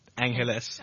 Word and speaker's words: Angeles: So it's spelled A-N, it's like Los Angeles, mm-hmm. but remove the loss and it's pronounced Angeles. Some more Spanish, I would say.